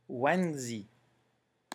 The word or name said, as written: wangzi